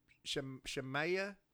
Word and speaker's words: Shemaiah: The rest of the events of Rehoboam's reign from beginning to end are recorded in the record of Shemaiah. Is that it? – Like Isaiah, because it's spelled S-H-E-M-A-I-A.